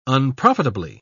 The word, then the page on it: unprofitably: British English